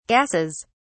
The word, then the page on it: gases: British English